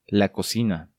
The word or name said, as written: La Cocina